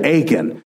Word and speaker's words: Achan: Man, I don't forget Achan because his name sounds like the word aching, like my back does.